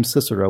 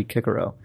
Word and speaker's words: Cicero: who pronounced his own name Cicero when it should be Kikero.